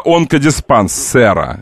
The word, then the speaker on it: онкодиспансера: Виктория Дворниченко, которая работает там, сейчас скажу быстренько, она главврач регионального онкодиспансера. Онкодиспансера, мне правильное ударение тут ставили.